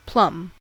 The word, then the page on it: plum: American English